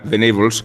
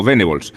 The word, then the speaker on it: Venables: ahora me a Igual que se pronuncia vegetables y no vegetables, es más adecuado pronunciar venables que no venables. Aunque eh, buena parte del debate español se resolvió llamando a Terry Venables, tal cual se leía, venables.